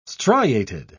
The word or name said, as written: striated